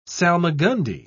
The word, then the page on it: salmagundi: British English